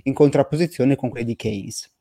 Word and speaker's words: Keynes: Praticamente mh, mm, insieme a Keynes, spero che si pronuncia così, John Maynard Keynes, Keynes.